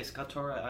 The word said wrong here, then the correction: Socotra: Socotra Island in Yemen.